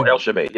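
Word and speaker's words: Elchibey: Um, I'll say his name wrong, Elechabi or something like that. – oh, Elchabi. Yeah, yeah, that guy.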